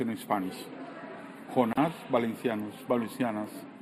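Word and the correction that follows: Valančiūnas: So the last name is Valanciunas.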